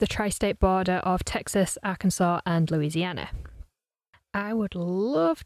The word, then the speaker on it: Arkansas: Texarkana is a border town straddling the state line between Texas and Arkansas, Arkansas, as it should be pronounced.